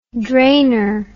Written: drainer

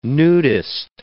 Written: nudist

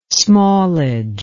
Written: smallage

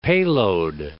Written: payload

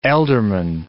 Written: ealdorman

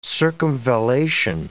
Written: circumvallation

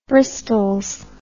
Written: bristols

